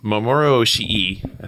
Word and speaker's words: Mamoru Oshii: So, so the director um, Mamoru Oshii, I think I said that right.